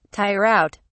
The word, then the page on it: tire out: British English